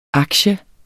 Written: aktie

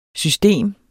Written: system

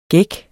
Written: gække

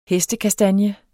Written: hestekastanje

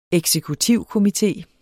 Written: eksekutivkomité

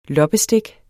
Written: loppestik

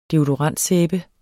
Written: deodorant

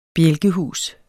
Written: bjælkehus

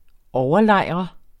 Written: overlejre

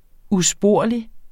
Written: USPORLIGE

